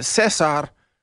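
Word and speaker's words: César: in Espanol, you would pronounce it Cesar.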